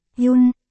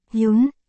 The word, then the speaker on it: Quân: Quân này là Jun, trong Quân Tử, phát âm là Jun, còn Quân trong tên Cố Quân là Yun, phát âm là Yun.